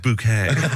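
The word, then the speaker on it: Bucket: Bucky, it's pronounced bouquet.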